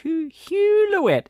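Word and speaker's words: Huloet: Written by Richard Hulouet? H-U-L-O-E-T. I don't have the first clue how to pronounce that. – Hulouet.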